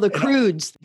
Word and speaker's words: The Croods: It's like the movie, The Crooks or whatever I butchered that name, but where they're in the cave people. – Oh, the Croods. – The Croods, yes, the Croods.